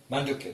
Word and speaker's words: Mandukya: I have been reading the, I hope you say this right, Mandukya. – Mandukya, this is what we are doing now.